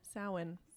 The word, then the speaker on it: Samhain: Samhain. – Samhain. – It's spelled Samhain. – Yeah, that's what I thought it.